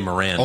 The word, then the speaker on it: Morant: There's no V. – Megan Morant. – Oh, Morant, yeah.